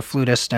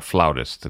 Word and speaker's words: flutist: So it's not flautist; it's flutist. – Well, the British say flautist. – Oh, okay. – I play the flute, not the flout, so I say flutist. But you know, the British say flautist, but as an American, I say flutist. You can say either way.